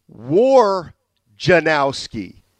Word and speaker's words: Wojnarowski: I've always said it Wojnarowski. Is that correct? He wrote back, hey, thanks, man. Appreciate it. Hope you're well. Wojnarowski is the way he would like it pronounced. Wojnarowski, despite the fact there's no R in there, but that's from the horse's mouth.